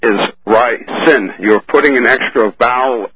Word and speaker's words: ricin: Ricin. R-I-C-I-N. You've been inserting an A after the I. Don't do that.